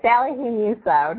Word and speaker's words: Salihu-Musso: Salihu-Musso, no problem.